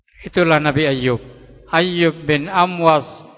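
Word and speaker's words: Amwas (أموص): Alif mim waw sot. Amwas ya bacanya.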